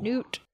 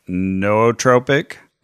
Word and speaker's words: nootropic: No, but not new like N-E-W either. – This is new like Chattanooga. – Yes. – N-O-O. – Noot. – And look, some people say it's pronounced nootropic. – Or nootropic. – Or nootropic. – Nootropic. That's what the lady on Google says.